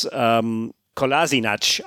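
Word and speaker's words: Kolašinac: And he said, it's Kolasinac in the Bosnian world, and it's Kolasinac auf Deutsch.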